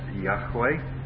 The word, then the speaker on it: Yahweh: The Hebrew is probably most accurately pronounced Yahweh.